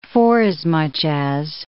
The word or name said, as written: forasmuch as